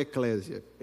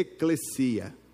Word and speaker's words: eclesia: Não é eclésia. Eclésia é o aplicativo da igreja. A pronúncia correta é eclesia.